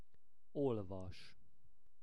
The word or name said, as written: olvas